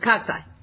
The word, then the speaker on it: cocci: See cocci, cocci. You actually pronounce it cocci.